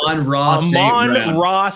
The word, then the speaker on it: Amon-Ra: Amon-Ra St. Brown. It's the sun god.